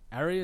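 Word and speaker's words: Aerea: It's A-E-R-E-A. Aria, is that how you'd pronounce that? – Yeah, Aria.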